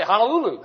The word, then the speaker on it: Honolulu: But I've got a brother-in-law from Hawaii, and they say Honolulu. Amen. That's the way they pronounce it.